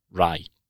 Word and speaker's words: Ryder: When you talk about that match in Glasgow, the West of Scotland, a lot of people pronounce it Ryder. It's the sound I as opposed to I. In England, America, they pronounce it Rye. There's no I sound in there. You don't call them. In England, you don't hear someone say he's a motorbike rider.